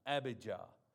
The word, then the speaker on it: Abijah: I'll say it right this time. Abijah.